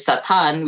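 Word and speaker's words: Satan (שָׂטָן): Or in Hebrew, the pronunciation is Satan, which means accuser.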